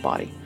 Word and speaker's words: Bawdy: Yes, I have a podcast, the Body Storytelling Podcast. Body is spelled B-A-W-D-Y, not B-O-D-Y. People get confused about that one. Mm-hmm. Body, like Mae West, like...